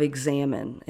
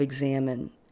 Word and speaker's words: examen: they utilized a practice called the prayer of examine, and it's spelled E-X-A-M-E-N, prayer of examine.